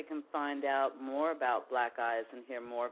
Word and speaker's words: BLAQUEAHYS: We're on Facebook, and it's BLAQUEAHYS. It's pronounced Black Eyes. You can friend us on Facebook. We're on Twitter, same way, Black Eyes.